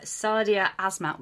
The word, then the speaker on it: Sadia Azmat: We are so excited today to have Sadia Azmat with us.